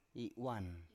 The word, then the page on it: i7uan: Biak